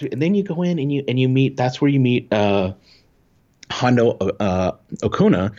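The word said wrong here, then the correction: Ohnaka: Hondo Onaka.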